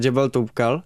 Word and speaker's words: Jebel Toubkal: A chtěli jsme vylíst na Jebel Toubkal.